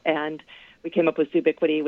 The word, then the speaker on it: zoobiquity: Catherine, in your previous book, you coined the word zoobiquity. What do you mean for that to describe? – So it's we pronounce it zoobiquity.